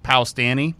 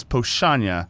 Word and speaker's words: Powstanie: Pochanya is pronounced exactly nothing like it's spelled. – Good. – All right, just so you know. – It's, just to make it easy, yeah. – Like you get the first syllable, and then after that, it's all over. It's a Polish word. So it's Powstanie. So it looks like Powstanie, but it's Pochanya.